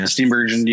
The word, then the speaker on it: Steenburgen: Steenburgen, yeah.